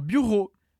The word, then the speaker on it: bureau: São só duas sílabas. Bureau. Aqui na segunda parte da palavra, a gente tem o som do R da garganta seguido de O. Então é ro.